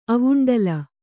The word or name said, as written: ಅವುಂಡಲ